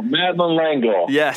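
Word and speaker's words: Madeleine L'Engle: It was chapter one, paragraph one, where you recount a story about Madeline, and I'm probably going to mess up the pronunciation of her last name. – Madeline Langle. – Yes.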